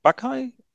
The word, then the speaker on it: Bukai: Ich spreche sie wahrscheinlich falsch aus. Hilf mir nochmal, wie sie richtig ausgesprochen wird. – Was tatsächlich Bukai.